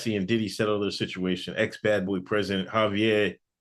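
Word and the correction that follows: Harve Pierre: But he dropped the Y on his name? – Oh, Harv. Oh, Harv Pierre. Harv Pierre.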